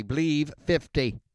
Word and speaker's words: Bleav: It's spelled B L E A V. Remember that. So the promo code is B L E A V 50, Believe 50, to get your 50% welcome bonus.